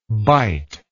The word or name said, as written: biteable